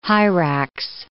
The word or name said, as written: hyrax